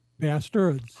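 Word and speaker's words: Basterds: According to Tarantino, the reason he spelled it that way is because that's the way it's pronounced. Bastards.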